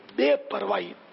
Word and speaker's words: بے پرواہی: بے پرواہی، صحیح اس کا تلفظ ہے، صحیح لفظ فصیح لفظ ہے بے پرواہی، ہی نہیں ہا نہیں آئے گا،